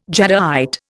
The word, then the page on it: jadeite: Indian